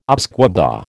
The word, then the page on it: Usquebaugh: American English